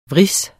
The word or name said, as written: vris